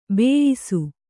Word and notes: ಬೇಯಿಸು: kannada

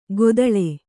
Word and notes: ಗೊದಳೆ: godaḷe

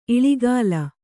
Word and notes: ಇಲಿಗುಲಿ: iliguli

